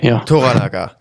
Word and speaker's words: Toranaga: Wir begleiten aber diesen Lord Toro Naga. – Toranaga, Toranaga, genau. So ist es ausgesprochen, richtig.